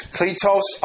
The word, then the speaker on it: kletos: Now, the two Greek words that I'm talking about are on the board right now, and it's kletos apostolos. Kletos Apostolos. You have the K-L-E, that's an E, it's a long E, T-O-S, and then the second word, A-P-O-S-T-O-L-O-S.